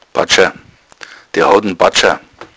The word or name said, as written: badscher